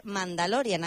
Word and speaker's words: Mandalorian: Así que vamos a empezar por lo primero de todo, porque has ido al cine a ver la nueva de Mandalorian, a ver si lo digo bien. – Sí, lo has dicho bien,